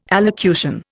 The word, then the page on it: allocution: Indian